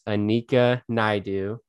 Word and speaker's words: Annika Naidoo: So our guest today is Annika Naidoo, and I pronounced it correct, right? – Yes.